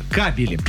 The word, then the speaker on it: кабели: Но правильно, если мы говорим про электрические сети, кабели.